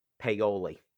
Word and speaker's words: Paoli: Is that, did I pronounce that properly? – Paoli. – Paoli.